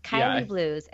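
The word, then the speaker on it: Kaili Blues: Yes, Kylie Blues.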